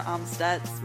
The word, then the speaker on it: Amstutz: Well, you can find me everywhere online at Sandra Amstutz.